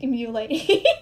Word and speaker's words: Mulaney: I hate how you say his name is John Mulaney. – Mulaney. I put too much emphasis on mule.